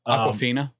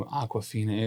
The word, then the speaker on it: Awkwafina: Awkwafina. Not Awkwafina. Awkwafina. – That's not how you say it.